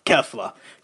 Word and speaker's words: Kefla: Kefla.